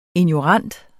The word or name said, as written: ignorant